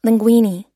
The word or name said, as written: linguine